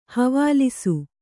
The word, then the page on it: ಹವಾಲಿಸು: havālisu